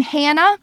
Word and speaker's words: Hannah: And if you want to check out my personal accounts, TikTok, Instagram, Facebook as well, it's my first name, Hannah, spelled the same ways, forwards and backwards, _Kessler, K-E-S-L-E-R.